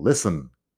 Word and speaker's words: listen: Y tú piensas, bueno, listen con la T, que suena. Y no, no es así. Luego, después de varios años de eso, llegas a mi clase y yo sí que enseño pronunciación y mucho. Y yo digo, listen la T no suena.